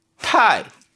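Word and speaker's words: 타이 (太): 사랑 애자는 발음을 I 하고 발음합니다. – I. – 그럼 클태자는? – 타이.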